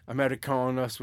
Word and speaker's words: Americanos: Americanos, I suppose, if you're being authentic.